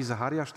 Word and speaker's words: Zacharias: Jeden z moich ulubionych nauczycieli, Rabbi Zacharias, albo Rabbi Zachariasz, tak się to wymawia, słynny taki dosyć apologeta, mówi o swoim wnuku.